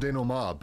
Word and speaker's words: denouement: denouement, and they're like, the what? And I'm like, oh wait, no, that's French. It's pronounced denouement.